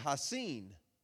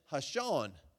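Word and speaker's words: Ha'Sean: And his name, his real name is Haseen. But everybody kept pronouncing it Hashan.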